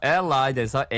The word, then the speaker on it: allied: Allied에서 A-L-L-I-E-D, 동종의 연관된, 이게 우리가 동맹관계라고 할 때 쓰잖아요. – That's interesting. You will hear native speakers with two pronunciations for this word. Allied or allied. – Mm, both are correct.